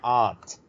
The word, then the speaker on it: aunt: Because you guys pronounce it aunt, as in A-N-T, like the creature. – Mm. – But it's everywhere else in the world that I know of is pronounced aunt.